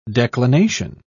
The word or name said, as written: declination